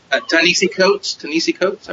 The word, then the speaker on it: Ta-Nehisi Coates: I don't want to butcher the author's name. Ta-Nehisi Coates? – Yes, that's it.